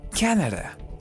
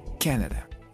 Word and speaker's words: Canada: Ahora, la segunda opción es Canadá, que se escribe igual que en español, Canadá, pero el acento la lleva en la primera sílaba y la D suena como una R. Canadá, Canadá.